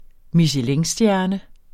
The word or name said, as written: michelinstjerne